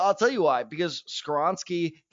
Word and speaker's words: Skoronski: The Las Vegas Raiders at number seven take Peter Skoronski. – Oh, you were saying it so good before we started. Skoronski.